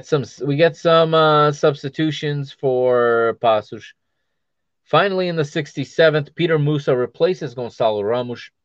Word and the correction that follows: Petar: Or should I say Petar Musa? That's the correct pronunciation.